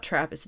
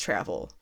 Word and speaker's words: Travel: It's not Travis, it's Travel.